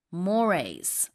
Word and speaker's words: mores: Mores, pronounced mores. We're talking about the customs, sort of the moral standards of a society or a culture. – Okay, so this is an interesting word. Spelling an mores지만 발음은 mores가 아니고요. Mores라고 해서 풍습이나 관습을 얘기합니다.